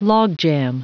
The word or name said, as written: logjam